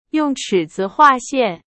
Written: 尺子

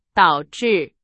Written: 导致

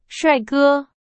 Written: 帅哥

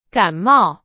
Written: 感冒

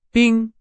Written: 兵

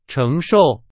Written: 承受

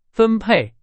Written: 分配

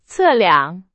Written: 测量

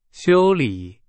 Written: 修理

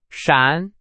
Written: 闪

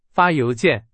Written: 邮件

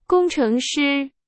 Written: 工程师

